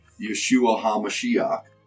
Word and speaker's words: Yeshua HaMashiach: The Old Testament Messiah in Hebrew is pronounced Yeshua HaMashiach, Jesus the Messiah.